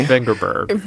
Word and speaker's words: Vengerberg: We should talk about Yennefer of Vengeborg, I guess it is. Hopefully I'm pronouncing that correctly. – Vengerberg.